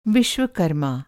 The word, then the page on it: विश्वकर्मा: Vishvakarma; masculine noun; Hindi